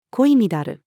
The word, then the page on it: 恋い乱る: female